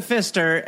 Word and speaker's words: Pfister: And it's the Pfister, and it doesn't help that it has a silent P.